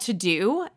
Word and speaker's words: Teux Deux: So hands down, it's called To-Do and it's spelled French, like T-E-U-X-D-E-U-X.